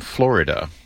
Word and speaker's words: Flo Rida: I know, but he's from Florida, so I like the Florida kind of pronunciation. – That I know that it's pronounced Flo Rida should just impress you today.